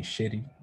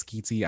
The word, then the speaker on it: Schiti: So I used to say shitty when I was younger, and I was just like, that just doesn't feel right. – Yeah, I feel like it's Schiti.